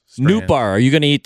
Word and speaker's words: Nootbaar: And then I realized the spelling of his last name, Nootbaar, it's N-O-O-T-B-A-A-R, Nootbaar. And I thought to myself, how many people have four vowels in their last name and only two syllables?